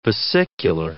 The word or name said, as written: vesicular